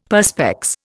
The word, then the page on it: Perspex: Indian